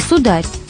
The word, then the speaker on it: сударь: А в русском устном бытовало слово «сударь» и «сударь» с ударением на второй слог, и даже дружественное «сударик».